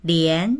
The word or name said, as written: lian2